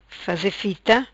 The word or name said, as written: Fita Faze